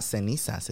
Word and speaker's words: cenizas: It starts with a C, and it's C-E-N-I-Z-A-S. – Well, then cenizas. Say it right. Yeah, it's not cenizas, it's cenizas.